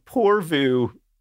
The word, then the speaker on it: Porvoo: Okay, so this place, I don't actually know if it's rural, but it's in a place called Porvu, and that is the correct Finnish pronunciation. Don't you worry your sweet little head about that one. Porvu, Finland, P-O-R-V-O-O, Porvu, like for you in French.